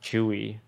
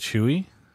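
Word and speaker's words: Tzuyu: Thank you to Magnarius, Mellowview, CJ Foxy Boy, Fool on a Hill, Boba T, Arsh, and Twice Zuyu. For the subs, we should have our next. – Pronounced Chewy after the Twice member. – Is it Chewy? – Yeah. – It's T-Z-U-Y-U.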